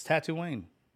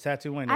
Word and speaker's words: Tatooine: It's Tatooine. – That's how you say it? – It's Tatooine.